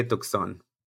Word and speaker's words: Tucson: Bueno, Tucson o Tucson? ¿Cómo se dice? – Tucson. Hay que decirle Tucson.